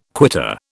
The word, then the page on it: quitter: British English